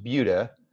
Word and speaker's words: Buda: Well, it's pronounced Buddha, but it looks like Buddha. Okay. So it's just BUDA.